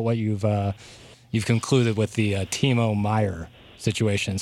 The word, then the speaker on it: Timo Meier: You have an article written yesterday about, and I'm just going to butcher the name here, but Timo Meier. Did I get it? – Yeah, you nailed it. Yeah. – Yes, Timo Meier, that could be a good fit, and there's some interest there.